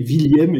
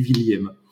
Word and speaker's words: Willieme: Donc, euh, moi, je le prononce Thierry William. effectivement. Quand on, dans le Nord, on dit plutôt William, mais, mais, mais, mon nom est plutôt d'origine de l'Est, donc on dit William.